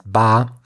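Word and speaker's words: Ba (八): It's called Ba, so the number is pronounced Ba,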